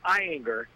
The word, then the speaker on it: Ayinger: It's pronounced Eyinger. That's the German pronunciation. – Okay. – A-Y-I-N-G-E-R.